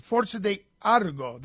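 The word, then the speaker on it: Forza d'Agrò: Next, fly to Sicilia, Sicily, for 4 nights in Taormina with special day trips along the coast, including Siracusa, Savacco, and Forza di Agra. No, I think I mispronounced it. Forza di Argo. That's it.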